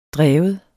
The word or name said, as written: drevet